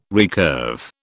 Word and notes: recurve: British English

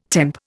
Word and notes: temp: Indian

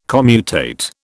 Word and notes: commutate: British English

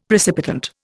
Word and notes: precipitant: Indian

